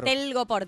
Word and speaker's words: Telgopor: Ay, sí, Tergopol no sé cómo se dice. ¿Cómo es Telgopor? – Telgopor, tela gomosa porosa.